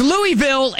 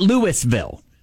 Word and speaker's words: Louisville: It was named after King Louis, so it's Louisville, and which we understand that. That's fine. That is an acceptable pronunciation. – Sure, absolutely. – But not Louisville.